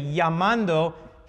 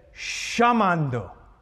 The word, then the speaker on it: llamando: If you ask an Argentine to say llamando, he says chamando, because that's the way Italians pronounce a double L.